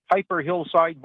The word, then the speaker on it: Piper Hillside: Correct me if I'm getting the pronunciation wrong. The Piper Hillside Boys and Girls Club? – Yeah, that sounds correct to me.